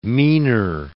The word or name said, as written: meaner